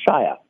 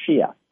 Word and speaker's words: Shia: This is also when I learned that his first name is pronounced Shia. I always thought it was Shia.